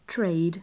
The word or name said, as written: trade